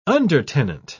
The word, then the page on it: undertenant: British English